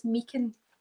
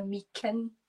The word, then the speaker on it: Meechan: It's Meechan.